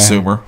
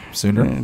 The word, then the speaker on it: Sooner: Nah, not Sumer. Sooner.